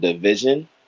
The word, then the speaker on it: DVSN: So the correct way to say their name is kind of funny, but their name is called Division. Yeah. That's how they pronounce their name.